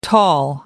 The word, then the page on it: tall: American English